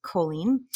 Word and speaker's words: choline: So my question for you, first and foremost is, what is choline? And am I pronouncing it correctly? – Yes, you're pronouncing it perfectly, of course.